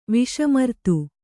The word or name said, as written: ವಿಷಮರ್ತು